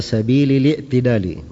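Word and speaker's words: سبيل الاعتدال: Kata beliau asy-syar'u fit taklifi wal amali jarin ala sabili i'tidali, sabili i'tidali. Ya. Jarin ala sabili li'tidali. Begitu bacanya, sabili li'tidali.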